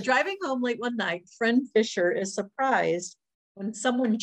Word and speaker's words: Phryne: And it says, driving home late one night, Fryn Fisher, is that how you say her name? – I have no idea. She has a very strange name. – She does, and it's spelled P-H-R-Y-N-E. That's way too many consonants.